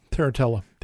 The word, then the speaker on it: Tarantella: Tarantella.